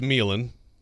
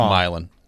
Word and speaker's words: Milan: You can start with, okay, I think that's Meelan. Am I right? – I think it's Milan.